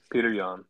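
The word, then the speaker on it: Petr Yan: My next one was, is it pronounced Peter? Peter Yan. – Peter Yan.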